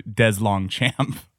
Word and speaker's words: DesLongchamp: And for once, I know I got the pronunciation about right because I actually asked him, how do you say your name? And he was nice enough to spell it out phonetically for me. – That's perfect. – He said there's probably like, you know, some French history there that, but like his family has dumbed it down to DesLongchamp.